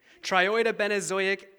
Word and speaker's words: triiodobenzoic: Early in his career, Arthur focused on trioidabenozoic acid. Did I say that right, Jason? Is he here? He left. Okay. Triiodobenzoic acid, which is a hormone that helps the flowering of soybeans.